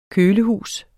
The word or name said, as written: kølehus